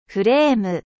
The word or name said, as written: cadre